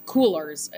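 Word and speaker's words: Coolors: And in this, I found, and I think you actually saw my tweet on this, I revisited a really cool tool called Coolers. I think it's like colors. It's spelled the American way with two O's.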